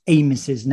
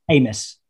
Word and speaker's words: Amos: We also apologise for not pronouncing David Amos's name very well. – How should you say it? – Amos, not Amos. It's like a hard A.